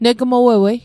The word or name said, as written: negmowowei